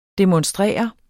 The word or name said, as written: demonstrere